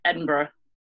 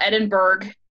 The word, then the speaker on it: Edinburgh: I'm going to butcher it, and I should know how to say it, Edinburgh. There we go. It's not Edinburgh. Please don't say that. It hurts me so much to my core.